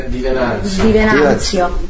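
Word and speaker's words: Di Venanzo: I can never say his name. Gianni Di Vienza? Gianni Di Vienza. – Di Venanzio.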